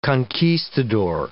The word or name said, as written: conquistador